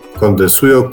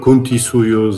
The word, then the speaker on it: Cuntisuyo: Prowincją, która się nazywa Kondesujo, Cuntisuyo, zależy jak będziemy wymawiali, interpretowali nazwę.